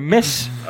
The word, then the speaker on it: mess: Ah, sorry, mijn uitspraak is niet helemaal geweldig, maar nee, never mess.